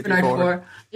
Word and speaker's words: Bernard Faure: Bernard Faure.